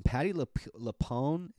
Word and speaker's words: Patti LuPone: It's, you know, obviously Joaquin Phoenix, Patti LuPone is how you say her name.